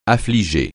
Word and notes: affliger: French

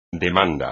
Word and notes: demanda: espagnol

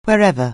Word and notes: wherever: anglais

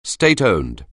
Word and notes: state-owned: anglais

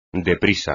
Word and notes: deprisa: espagnol

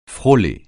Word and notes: frôler: francais

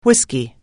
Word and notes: whisky: anglais

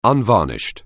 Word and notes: unvarnished: anglais

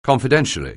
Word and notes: confidentially: anglais

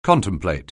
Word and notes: contemplate: anglais

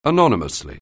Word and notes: anonymously: anglais

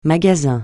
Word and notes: magasin: francais

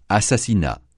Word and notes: assassinat: francais

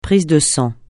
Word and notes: prise de sang: francais

